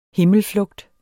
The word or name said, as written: HIMMELFLUGTS